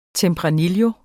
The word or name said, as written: tempranillo